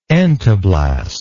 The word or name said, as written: entoblast